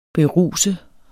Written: beruse

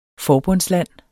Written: forbundsland

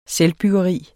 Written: selvbyggeri

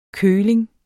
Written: køling

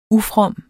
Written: ufrom

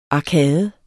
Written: arkade

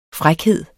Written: frækhed

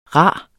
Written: rar

